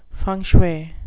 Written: feng shui

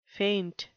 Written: feint

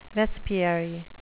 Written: vespiary

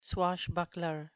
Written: swashbuckler

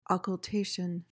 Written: occultation

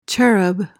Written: cherub

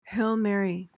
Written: hail mary

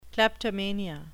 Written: kleptomania

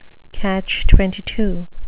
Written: catch-22

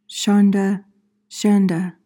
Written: shonda

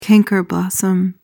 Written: canker-blossom

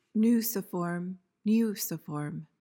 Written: nuciform

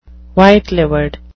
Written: white-livered

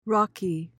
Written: rawky

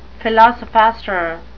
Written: philosophaster

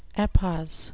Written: epos

